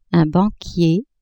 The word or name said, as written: banquier